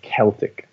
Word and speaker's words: Celtic: And the key thing is that you pronounce it with a hard C, right? A K sound, Celtic. And this is because the Greeks were the first to name these people and they called them Celtoi. So that was the pronunciation. So this is why we say Celtic rather than Celtic.